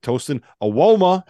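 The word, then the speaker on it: Tosan Evbuomwan: If you can find a way to take advantage of that style, space out the floor, like Tosin Awoma. How about that pronunciation, T.O.? You like that, CZ? – Very, very well done.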